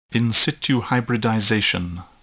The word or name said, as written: in situ hybridization